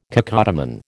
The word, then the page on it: cacodemon: American English